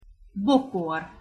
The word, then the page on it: bokor: Hungarian